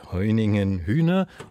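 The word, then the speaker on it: Hoyningen-Huene: Die Berliner Galerie Jäger Art erinnert mit einer neuen Ausstellung ab morgen an George Hoeningen Hühne.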